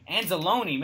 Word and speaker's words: Anzalone: Is it Anzalone? – Anzalone, man.